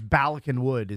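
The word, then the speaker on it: Bala Cynwyd: So Balasinwood or something like that? – I think it's Balakinwood is how it's pronounced.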